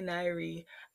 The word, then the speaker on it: Niyre: _ I'm only Niyre _.